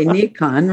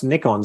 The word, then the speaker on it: Nikon: Well, I mean, you got to remember that when you say we, Nikon is a Japanese company and it's pronounced Nikon. So I, I, every American is saying it incorrectly.